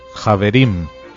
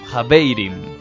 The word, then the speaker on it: javerim: Javerim. – Compañeros o camaradas en hebreo. La misma palabra se utiliza en yiddish, pronunciándose en este caso Javeirim.